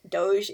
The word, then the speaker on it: Doge: Doge. It's really just dog, but they made it fancy.